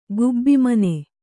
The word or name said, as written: ಗುಬ್ಬಿಮನೆ